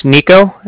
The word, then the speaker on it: Niko: So, like I said, my name's Nico, and that's spelled N-I-K-O, and the Bank of Cardiff is spelled C-A-R-D-I-F-F, as in Frank, so it's like car and then different.